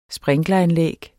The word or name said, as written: sprinkleranlæg